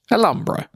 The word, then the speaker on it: Alhambra: It's a Spanish word, so the H is silent. Alhambra.